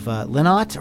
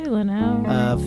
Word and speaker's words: Lynott: Yeah, Phil Lynott of Lynott or Leno? How do you say that? – I say Leno.